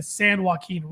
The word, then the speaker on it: San Joaquin: Hodges was eventually tracked down to a secluded camp near the San Joaquin River. Yes, I pronounced that correctly for once. – Yes, he did.